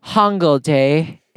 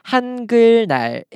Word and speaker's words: 한글날: Due to romanization, we pronounce it as Hangul Day in our school, but it's actually 한글날 in the rest of Korea.